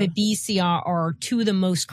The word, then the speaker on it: Babesia: but I don't know how you pronounce it. Babesia. – Yes.